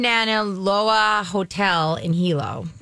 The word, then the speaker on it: Hilo: H I L O is the island. Uh, oh, Hilo. It's Hilo. Hilo. Hilo. They're in Hilo.